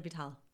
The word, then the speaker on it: Vitale: Amber Lynn Vitale. – Vital. And Ramy Mon, right? – Ramy Mom. – Ramy Mon. – M-A-M.